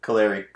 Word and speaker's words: Kaleri: Kaleri. At Kaleri _. – All right, you know better than K-A-L-E-R-I. – Me. K-A-L-E-R-I.